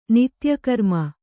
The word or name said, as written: ನಿತ್ಯಕರ್ಮ